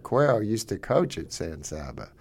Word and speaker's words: San Saba: I will be there Friday in Kyle against San Saba. Is that how you say it? – San Saba. – San Saba.